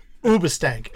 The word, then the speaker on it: Hoobastank: Hoobastank? – No, they weren't a punk band. Ubastank is actually the proper way to pronounce it. But not Uba.